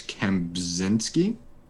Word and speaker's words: Kempczinski: Chris Kempsowski. – That's not Irish. Chris Kempczinski. Kempczinski.